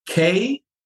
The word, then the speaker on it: kiiking: If you look in Norway, there's this sport called, it's spelled K-I-I-K-I-N-G, ki-iking, right?